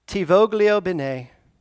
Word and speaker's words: ti voglio bene: They pronounce it ti voglio bene, and it means literally I wish you good, or I want what's good for you.